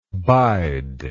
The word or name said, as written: bider